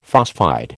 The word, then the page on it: phosphide: American English